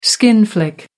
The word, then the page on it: skin flick: American English